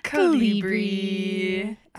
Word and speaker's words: Calibri: Calibri.